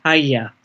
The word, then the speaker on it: Ajna: In the translation from Sanskrit to the modern Western alphabet, Agya got written down as A-J-N-A, which is why people who haven't heard it being pronounced by Indians call it Ajna. Whereas in India, certainly in the Northern Indian ashram I was in, it was pronounced Agya.